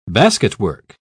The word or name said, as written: basketwork